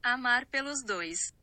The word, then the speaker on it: amar pelos dois: 발음은 이렇습니다. amar pelos dois.